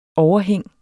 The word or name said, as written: overhæng